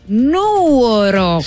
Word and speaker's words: Nuoro: Nuoro, si con l'accento, la sull'accento sulla U.